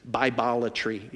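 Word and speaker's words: bibliolatry: It's bibolatry. It rhymes with idolatry.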